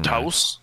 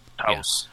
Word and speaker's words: Taos: Taos. Okay. – Taos. Yeah. There we go. – Thank you, Wikipedia. The Taos hum.